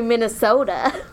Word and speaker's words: Minnesota: I know how to say Minnesota. – Minnesota. – Minnesota. – Like you're ordering a small drink.